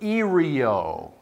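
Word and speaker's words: ereo: Now, the word say is a little Greek word, erio. It's not spelled like Erie, Pennsylvania. It's E-R-E-O. Erio.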